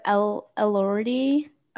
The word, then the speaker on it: Elordi: Jacob Elordi.